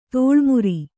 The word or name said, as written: ತೋಳ್ಮುರಿ